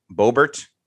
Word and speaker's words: Boebert: Lauren Boebert.